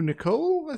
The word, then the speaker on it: Niccol: The director, such as you mentioned him, is Andrew Niccol, I think is pronounced. – Yes, who also wrote it, writer-director.